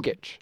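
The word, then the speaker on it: Jokić: How do you pronounce the last name? Jokic.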